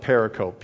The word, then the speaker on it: pericope: It's spelled like pericope, P-E-R-I-C-O-P-E.